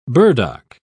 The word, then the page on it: burdock: British English